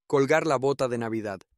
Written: colgar la bota de Navidad

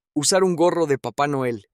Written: usar un gorro de Papa Noel

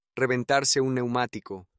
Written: reventarse un neumatico

